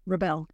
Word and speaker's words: Rebelle: So So having said all of that, tell me, you know, your last name is pronounced? – Rebelle.